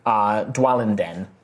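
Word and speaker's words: Dwalinden: you vaguely remember something about a dwarven town called Dwaladen. Dwalenden, sorry, Dwalenden. – Could you spell that for me? – D-W-A-L. – A-L-I-N. I-N. – D-E-N.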